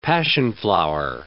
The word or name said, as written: passionflower